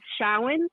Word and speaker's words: Samhain: I have those beliefs, and this is Samhain. And it's spelled Samhain. You know, if you see the word Samhain floating around, it's actually Samhain in Gaelic.